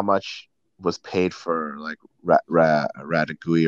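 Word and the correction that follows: Retegui: or whatever his name is. I don't know how to say it, but Retegi, yeah. – Yeah, Retegi.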